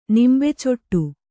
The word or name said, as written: ನಿಂಬೆಚೊಟ್ಟು